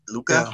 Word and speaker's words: Luca: Luca.